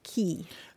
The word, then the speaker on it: Quay: Yeah, it's an odd one, but QUAY is usually pronounced key.